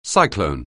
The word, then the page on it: cyclone: anglais